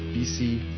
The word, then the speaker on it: Bisi: Martin BC and Band. – Okay. Ja, ne? – Also geschrieben eher irgendwie Martin, klar, und dann B-I-S-I, aber BC wird's ausgesprochen, Alter.